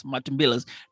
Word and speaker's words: Matumbilas: It's the Matumbilas, right? Did I say it right? Yes, Matumbilas.